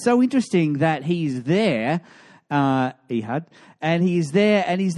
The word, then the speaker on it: Ehud: I was going with Ehud, but she said, no, it's Ehud. So we're going with Ehud this morning.